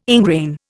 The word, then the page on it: ingrain: Indian